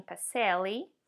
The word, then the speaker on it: Pacelle: And I'm holding in my hands the book that is called The Humane Economy by Wayne Pacelli. Right? Is that how you say it? – That is, yes.